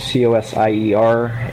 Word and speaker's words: Cozier: Yeah, if you go to YouTube and just type in my name, Brian Cozier, my last name is Cosier, and Brian is spelt with an I.